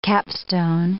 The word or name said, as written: capstone